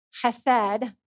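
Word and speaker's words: chesed (חסד): It's from the Hebrew word chesed. And if you're going to pronounce it correctly, you have to have a little phlegm in the back of your throat.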